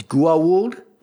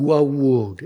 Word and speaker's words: Goa'uld: I do think it's three syllables. It's Goa'uld. That's how Teal'c pronounced it in the first one. I believe that's how Kendra pronounces it here. Mm-hmm. Goa'uld.